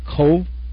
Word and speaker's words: Cobh: Now, although it's spelled C-O-B-H, you pronounce it Cove? – Yes, with a V sound. I understand in Gaelic that they don't have the letter V, but it is pronounced like we would pronounce Cove.